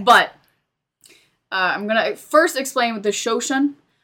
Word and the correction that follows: Shoshone: I am not pronouncing it right. Let me look it up. – I think you might be. – Shoshone. – Okay.